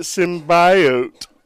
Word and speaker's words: symbiote: Do you guys think they're gonna have other symbionts? – I don't think so, man. – You're supposed to pronounce it symbiote.